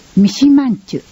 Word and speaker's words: 三島人: 青少年指導者養成研修、三島人と書いてミシマンチュと読みます。